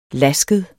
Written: lasket

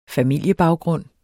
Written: familiebaggrund

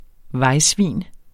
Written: vejsvin

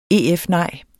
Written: EF-nej

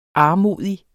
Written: armodig

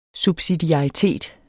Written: subsidiaritet

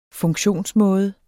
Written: funktionsmåde

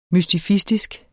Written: MYSTIFISTISKE

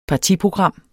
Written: partiprogram

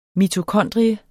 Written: mitokondrie

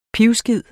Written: PIVESKID

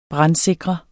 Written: brandsikre

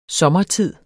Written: SOMMERTIDER

